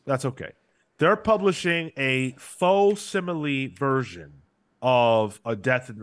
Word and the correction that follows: facsimile: It's pronounced facsimile.